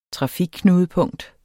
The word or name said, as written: trafikknudepunkt